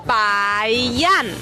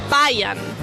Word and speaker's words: Bayern: Y además, en alemán, cuando en una misma sílaba la R está delante de una consonante, suena como A. Por lo tanto, es Bayern. Bayern. Bayern.